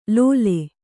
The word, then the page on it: ಲೋಲೆ: Kannada